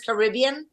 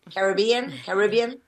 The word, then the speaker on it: Caribbean: Yo digo Royal Caribbean, pero es Caribbean. Caribbean. – Mira, depende de quién lo pronuncie. Todos son aceptados.